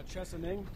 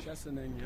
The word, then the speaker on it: Chesaning: Chesaning. – Chesaning? – Chesaning, yep.